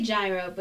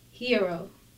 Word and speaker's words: gyro: I say gyro, but it's pronounced hero.